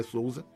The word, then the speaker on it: Sousa: O sobrenome luso, que os americanos, como Ralph me lembrou, pronunciam como Sousa, mas eu, como descendente de português, me recuso, para mim é Sousa.